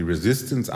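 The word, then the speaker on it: Résistance: Wenn er 35 Jahre alt ist, wird er die sogenannte Resistance, so wird französisch ausgesprochen, die Resistance anführen,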